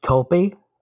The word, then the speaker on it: tulpae: Um, some people insist that it's tulpi, T U L P A E, but it's also not a Latin word, so that's where it's a problem.